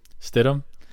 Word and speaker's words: Stidham: I mean, if I'm not even sure how to say his last name, is it Stidham? – Stidham.